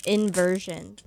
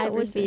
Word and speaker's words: Iverson: I've been listening to this band for a while, and I kid you not, I have been reading their name wrong the entire time. – What were you saying? – I think I was saying, like, inversion. Blue inversion. – Nope.